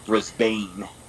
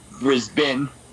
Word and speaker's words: Brisbane: Americans will think it's called Brisbane, but it's Brisbane.